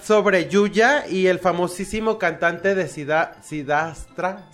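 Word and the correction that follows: Siddhartha: Sidarta. – Sidarta.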